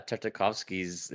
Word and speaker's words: Tartakovsky: Is it Tartakovsky? – Yeah, Tartakovsky's.